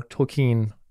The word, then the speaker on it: Tolkien: Tolkien, if I'm saying the name right.